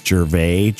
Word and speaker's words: Gervais: Gervais.